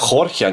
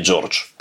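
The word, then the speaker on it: Jorge: И, э, ну, ну, как это, слушайте, вот имя Хорхе, например, оно по-английски пишется хрен знает как, но его же произносится как а Хорхе, а не Джордж.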